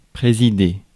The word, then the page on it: présider: France; /pʁe.zi.de/